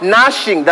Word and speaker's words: gnashing: And cast the unprofitable servant into the outer darkness, there will be weeping and gnashing, that G is silent, of teeth.